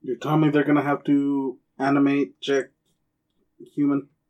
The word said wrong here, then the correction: Hugh Jackman: Oh my god, I butchered that name. – You really did. I don't know who you were talking about. – That was Hugh Jackman.